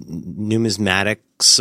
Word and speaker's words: numismatics: But, when you think about what you described, like somebody going to Wall Street or somebody who's going to be in numismatics or whatever, like, I think it's important. Did I pronounce that even anywhere near right? – Yeah, you know, it was good.